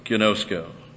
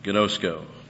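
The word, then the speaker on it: ginosko (γινώσκω): This is the word, ginosko. Long O's, ginosko, to know.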